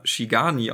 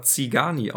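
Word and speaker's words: Țigani: Wir haben die, ähm, äh, das fahrende Volk, was auf die Burg kommt, haben wir immer Shigani ausgesprochen, mm. weil das hm. in der englischen Version mit SZ geschrieben wird. Tatsächlich spricht man das aber Zigani aus hm.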